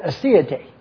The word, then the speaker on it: aseity: You can't believe how much time I've spent on the word aseity.